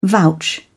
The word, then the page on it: vouch: British English